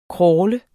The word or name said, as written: crawle